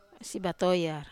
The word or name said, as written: batoyar